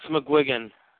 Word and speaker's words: McGuigan: It's McGuigan.